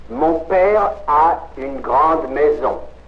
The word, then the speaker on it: maison: Maison. – Non, c'est le Z. Z. Maison. – Maison. – Maison. You see, when the S is between two vowels, you pronounce it like a Z.